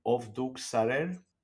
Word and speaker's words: Ovduk Sarer: My favorite, and Ron, correct me if I'm not pronouncing it well, Ovduk Sarer. – Oh, beautiful. Your Armenian is excellent.